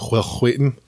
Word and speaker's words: Wheaton: And you can see the progression of Will Wheaton. As he progresses. Like, that is the correct pronunciation, guys. I don't want to hear otherwise.